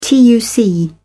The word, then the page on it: tuc: British English